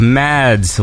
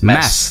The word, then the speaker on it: Mads: Well, it's less cool when I tell you that the D is silent, I think. So it's not Mads. Oh. It's not like I'm a mad dude, but boo!